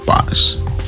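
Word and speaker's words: Bosc: I believe it's Bosch. I mean, I believe it's Bosch. I've always pronounced it. I've heard it. It's B-O-S-C.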